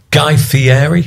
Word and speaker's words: Guy Fieri: And let me see if I've got this guy's pronunciation. Guy Fieri? – Yep, have I said it? – Him.